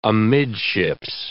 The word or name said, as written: amidships